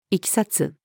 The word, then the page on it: 経緯: female